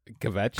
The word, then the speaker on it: kvetch: Did I say that right? – You did. – Yes. – How do you pronounce that again? Say that again. – Kvetch.